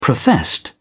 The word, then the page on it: professed: British English